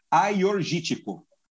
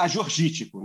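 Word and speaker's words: Agiorgitiko: E lá é a terra da Aiorgítico, né? Então aí o produtor explicou como se falar, porque para mim era Ajorgítico, né?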